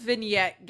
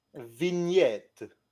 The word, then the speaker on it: vignette: Where does the word vignette get off being pronounced the way it is? – Well, it's French. – G-N... – Vignette.